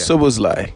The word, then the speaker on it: Szoboszlai: And that's midfielder Szoboszlai. He's moved to, thank you, pronounce it, collect it. – Yeah. Szoboszlai.